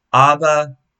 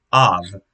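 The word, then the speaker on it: Ab (אב): The Hebrew word for father is Ab, or depending on how you want to pronounce that, it could be pronounced Av, because that last letter could be pronounced, or at least at some point was pronounced like a V, but has turned into a V sound in some dialects.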